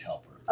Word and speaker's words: Koelper: How do you pronounce the O-E? – I'm pretty sure it's pronounced Culper. – Oh, well, so Joel would be Cole. I'm going to say Culper. K-O-E-L-P-E-R.